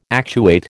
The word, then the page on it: actuate: American English